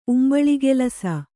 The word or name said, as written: ಉಂಬಳಿಗೆಲಸ